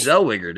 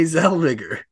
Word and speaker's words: Zellweger: I think it's Zellweger, dude. – Renee Zellweger. – Yeah, it's Renee Zellweger. It is. I think it is. I'm pretty sure it is.